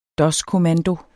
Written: doskommando

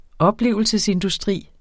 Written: oplevelsesindustri

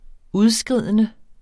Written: udskridende

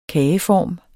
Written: kageform

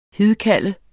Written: hidkalde

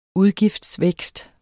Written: udgiftsvækst

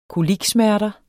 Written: koliksmerter

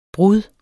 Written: brud

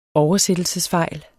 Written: oversættelsesfejl

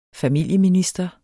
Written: familieminister

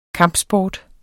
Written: KAMPSPORTEN

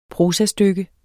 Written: prosastykke

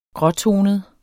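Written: gråtonet

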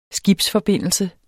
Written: skibsforbindelse

